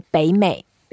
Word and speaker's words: 北美: So we say 北美.